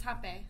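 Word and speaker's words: rapé: Okay, so it's spelled R-A-P-E with a tilde, but it's... – Oh, guys, hape? – Yeah, but you guys say hape. – It's hape.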